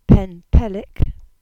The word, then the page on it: Pennpellik: Cornish